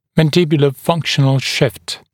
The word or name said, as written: mandibular functional shift